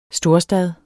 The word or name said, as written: storstad